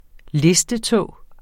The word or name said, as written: listetå